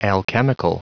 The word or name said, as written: alchemical